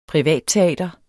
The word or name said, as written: privatteater